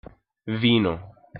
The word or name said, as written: vino